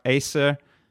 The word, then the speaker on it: Aesir: Now, the world-renowned Norwegian explorer and scientist, Thor Heyerdahl, he believed that the Aesir, it's spelled with that A-E blended letter, A-E-I-S-I-R, Aesir,